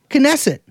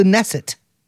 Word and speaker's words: Knesset: Am I saying it right? – Yeah, that's how you say it. – Knesset. – Yeah. – We don't love that. – No. – The Knu. – Like a Knish. – It's the Knesset.